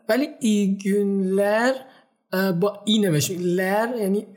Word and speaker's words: günler: خب میشه اینو برامون بازش کنی این گون با یو هستش درسته با یو که دو نقطه بالاش داره پس اینو باید ما او تلفظ کنیم هم ای گونلر و هم گونایدن با او تلفظ میشه درسته درسته تلفظت خیلی درسته